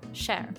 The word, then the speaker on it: Cher: La historia se centra en Cher, una adolescente, fíjense qué bien lo pronuncié, Cher,